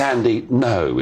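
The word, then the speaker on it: Andy Ngo: N-G-O. Is it Andy Ungo? I think it's Andy No is how you pronounce it. Is that right? – Yes, it is. – It's Hello. Andy No. It's a silent G.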